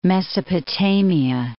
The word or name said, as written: Mesopotamia